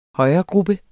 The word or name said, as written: højregruppe